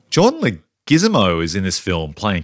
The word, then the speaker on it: Leguizamo: John Leguizamo. John Leguizamo. – So basically neither one of us pronounced it correctly in the first instance.